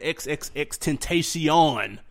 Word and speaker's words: XXXTentacion: And we had XXXTentacion. – Is that how you say it? – That's how you say it, man.